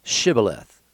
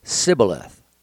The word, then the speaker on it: Shibboleth: When he said no, they said to him, then say Shibboleth, which means like a rushing stream. And he said, Sibboleth, for he could not pronounce it right.